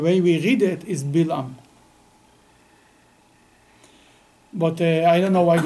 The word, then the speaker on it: Balaam: Yes, in some of the non-Orthodox books, it's spelled B-A-A-L-A-M. And I've heard it pronounced Balaam, and I've heard it pronounced Bilam. – I read Hebrew. I don't read good English. I can tell you that in Hebrew it's Bilam.